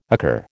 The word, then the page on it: occur: American English